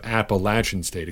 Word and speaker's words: Appalachian: For a guy who's even more in the Aaron Jones mold, look at Darrynton Evans out of Appalachian State, Appalachian State, excuse me, if you're looking for the local pronunciation.